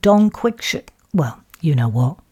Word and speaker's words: Don Quixote: You said Don Quixote. It's pronounced Don Quixote.